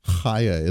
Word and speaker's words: Chaya: Chaya is how you pronounce her name.